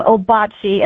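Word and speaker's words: Ouabache: But then in 1962, we got that name, Obachi, and that's how the DNR does pronounce it. We pronounced it that way pretty much since 1962, and it's a French spelling for the Miami Native American word for the Wabash River.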